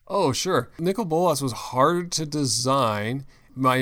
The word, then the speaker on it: Nicol Bolas: Nicol Bolas.